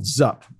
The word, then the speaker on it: Xup: We bought another fintech company just last year called Zup, spelled Z, I'm sorry, X-U-P, but pronounced Zup.